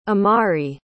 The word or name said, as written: imari